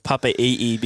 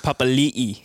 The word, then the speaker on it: Papali'i: I love how you say Papa'i'i, because that's not even the right pronunciation. Papali'i. – Papali'i. – You're forgetting the L.